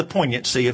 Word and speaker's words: poignancy: Well, finally, note the poignancy of his anxiety. Children, if any of you are taking notes tonight, poignant has a silent G. It's P-O-I-G-N-A-N-C-Y. Poignancy refers to the idea of being sharply painful to the feelings, a piercing, evoking pity, emotionally touching or moving.